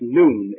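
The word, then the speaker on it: noon (νῦν): The word now in Greek is spelled N-U-N. Don't be bothered by it. It's pronounced noon, N-O-O-N.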